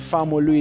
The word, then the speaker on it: Famolu: Sam, S-A-M, then Samuel, full Samuel, that's S-A-M-U-E-L, then Famolu is F-A-M-O-L-U.